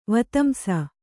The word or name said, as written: ವೀತಂಸ